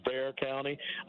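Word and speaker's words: Bexar: And the, the pronunciation is Bear County. I know it's real funny, but uh, it goes back to the Hispanic uh, nomenclature.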